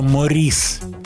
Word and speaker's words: Морис: Я бы раньше сказал Морриса и Робина, но к своему удивлению узнал, что Морис читается его имя правильно – у него было имя с французским ударением – Морис.